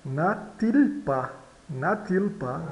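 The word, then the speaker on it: n'a-t-il: Observe aqui o T. Geralmente ele é mudo, né? Mas quando ele segue uma vogal, então ele deixa de ser mudo, né? Então, natil pa, não tem ele.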